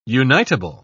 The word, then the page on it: unitable: British English